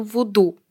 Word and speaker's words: вуду: и именно в гаитянском вуду. Как говорит Валентин, это правильно произносить вуду.